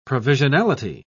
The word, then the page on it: provisionality: British English